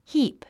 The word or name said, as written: heap